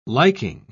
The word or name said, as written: liking